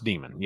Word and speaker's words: Daemon: Spelled Daemon, but pronounced Demon.